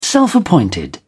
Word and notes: self-appointed: British English

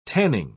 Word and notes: tanning: British English

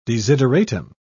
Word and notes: desideratum: British English

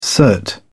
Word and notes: cert: British English

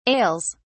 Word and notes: ails: British English

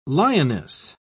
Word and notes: lioness: British English

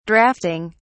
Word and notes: draughting: British English

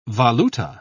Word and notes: valuta: British English

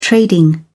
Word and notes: trading: British English

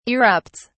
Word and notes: erupts: British English

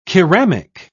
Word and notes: keramic: British English

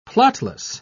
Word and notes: plotless: British English

